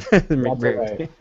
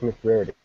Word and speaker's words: McBrearty: McBrearty. Right. – Well, as you say it. Say it again. – I just pronounced it Patrick McBrearty.